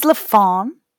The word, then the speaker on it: Lafond: It is Lafond. There's a sort of a silent DS.